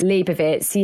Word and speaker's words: Leibovitz: And because the cover, also read about the cover, it's an Annie, is it Leibovitz? I never know how you pronounce her surname, that photographer. – Annie Leibovitz. – Leibovitz, yeah.